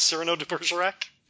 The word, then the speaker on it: Cyrano de Bergerac: That's how you pronounce Cyrano de Bergerac? – Cyrano de Bergerac.